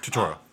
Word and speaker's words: Turturro: I'm going to mispronounce his name like crazy, but I love him. I've loved him since Big Lebowski. John Turturro. – Turturro. – Okay, I wasn't that close. Yeah. Close. That was close.